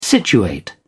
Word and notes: situate: British English

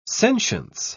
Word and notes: sentience: British English

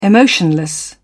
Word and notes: emotionless: British English

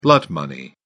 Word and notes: blood money: British English

